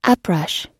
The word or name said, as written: uprush